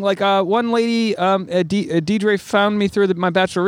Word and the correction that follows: Deirdre: You say it wrong every time. – Deirdre? – Yeah, you do. – Sorry, Deirdre. I really fuck it up every time.